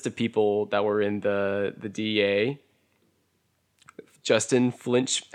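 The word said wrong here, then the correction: Finch-Fletchley: he made it say it wrong. Justin Finch Fletchy.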